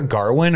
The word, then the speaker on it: Gawain: Gawain the whole time. Did you did you hear Garwin or was that just like a dialect thing? Uh, it's like uh, Old English or Middle English pronunciation.